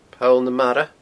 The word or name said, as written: Poll na Mara